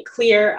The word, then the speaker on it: Xlear: So I usually say like a clear X-L-E-A-R, it's spelled with an X, but it's still pronounced clear, nasal spray is a really good one to use.